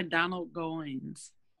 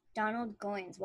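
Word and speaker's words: Donald Goines: I wonder if you would like books by Donald Goins. – Donald Goins. Well, we'll find out because I'm going to Google him. How do you spell that? Donald Goins. G-O-I-N-E-S.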